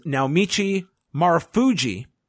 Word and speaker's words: Naomichi Marufuji: Michael Elgin versus Naomichi Marufuji, which is how you say his name.